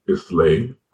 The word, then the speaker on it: Islay: And the Highlands, one looks like Islay, it's pronounced Isla, I-S-L-A-Y.